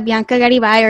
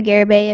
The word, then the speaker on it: Garibay: Like Harrison said, my name is Bianca Garibay, or Bianca Garibay, if that's easier to pronounce.